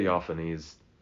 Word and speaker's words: Theophanes: Yeah. Theophanes. – That sounds right.